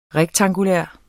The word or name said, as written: rektangulær